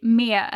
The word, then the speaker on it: med: I really like how you say med as well, and not just med. Uh-huh. Because if you're a native speaker, you'll most likely say me and you won't really pronounce the D at the end of that word.